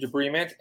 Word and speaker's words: debridement: So, uh, uh, arthroscopic it's, it's pronounced arthroscopic debridement, and debris meaning like mm-hmm. debris, trash, so to speak.